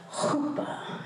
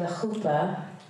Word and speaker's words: chuppah: And they would exchange their covenant vows before God and family, and then they would enter into what was called the chuppah. Did I say that right? You have to kind of get a cat, like, you know, hairball kind of thing going on.